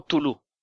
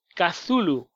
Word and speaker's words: Cthulhu: Pero por lo visto y según he escuchado y según la pronunciación americana, ya que el escritor era estadounidense, la pronunciación que hacen ellos es Cthulhu. – Cthulhu, sí, exactamente.